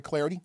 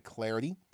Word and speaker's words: Clardy: Give me the follow at Troy Clarity. Last name is spelled C-L-A-R-D-Y, at Troy Clarity.